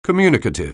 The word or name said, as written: communicative